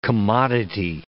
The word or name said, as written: commodity